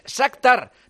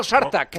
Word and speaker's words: Shakhtar: No, es al revés, es Shakhtar, no Shartak.